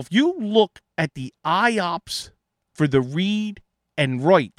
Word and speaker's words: IOPS: Something I noticed, though, a metric that's being used, it's called IOPS. That's how you pronounce it. IOPS, and it's IOPS.